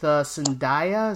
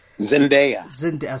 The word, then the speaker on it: Zendaya: And this is the new show with Zendaya. Is that how you say her name? – Zendaya. – So I said it wrong.